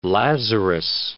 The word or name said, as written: Lazarus